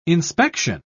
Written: inspection

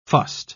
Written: fussed

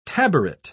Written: taboret

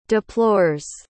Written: deplores

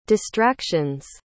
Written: distraction's

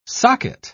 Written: socket

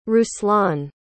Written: ruslan

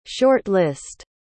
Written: short-list

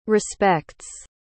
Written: respects

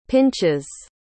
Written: pinchas

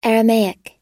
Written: Aramaic

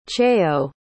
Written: chayo

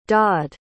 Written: dodd

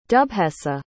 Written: dubheasa

